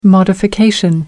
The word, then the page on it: modification: American English